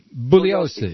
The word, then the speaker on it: Bugliosi: Yes, G before L is silent. – Is Vince Bugliosi? – You did a good job.